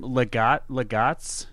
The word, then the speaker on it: legates: Legats? L-E-G-A-T-E-S. I would pronounce it legats. Would you call it legates?